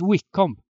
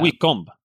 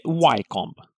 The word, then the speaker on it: Wycombe: Jag läste att det uttalas Wickham. Wickham. – Ja, det är så man säger. Wickham. – Wickham. – Inte Wycombe. – Nej, precis. Wickham.